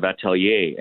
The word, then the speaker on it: atelier: Yeah, really good question, and that flows back to what you said earlier in our session about, you know, the definition of atelier. And by the way, you pronounced it great.